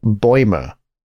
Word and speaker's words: Bäumer: If you listen carefully, it's Paul Bäumer is how what we see as Baumer is pronounced.